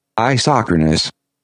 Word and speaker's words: isochronous: Isochronous.